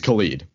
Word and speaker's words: Khalid: Yeah, you called him Khalid. It's Khalid, pop singer.